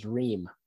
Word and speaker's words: Reum: It's actually pronounced Reum. – Reum. – Doesn't make any sense. Yeah. – Right. – R-E-U-M. But in my world, it is Reum.